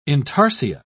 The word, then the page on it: intarsia: British English